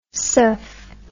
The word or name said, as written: suf